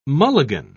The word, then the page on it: mulligan: British English